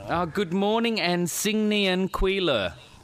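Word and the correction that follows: Xing Nian Kuai Le (新年快乐): How would you actually pronounce it? – Okay. Oh, yeah. It's Xing Nian Kuai Le.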